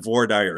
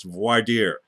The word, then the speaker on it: voir dire: He pronounced it just how it was spelled. That's not how it's pronounced. It's not voir dire. It's voir dire, where you see what the members of the jury have to say.